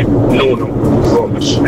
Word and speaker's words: Nuno Gomes: Visto che ci si vanta delle pronunce portoghesi, è Jorge Mendes, così come mi tocca correggere anche il buon direttorissimo Loreto, con Nuno Gomes, non si può più sentire, è Nuno Gomes.